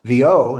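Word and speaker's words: Vieaux: My guest has been Grammy winning classical guitarist, Jason Vio. That's how it's pronounced.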